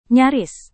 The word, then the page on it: nyaris: Indonesian